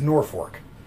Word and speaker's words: Norfolk: It's spelled Norfolk, but it's Norfolk.